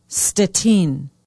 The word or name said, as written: stettin